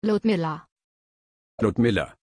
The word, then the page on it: Ludmila: German